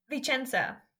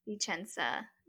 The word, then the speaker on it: Vicenza: It's V-I-C-E-N-Z-A. – Vicenza. – Vicenza.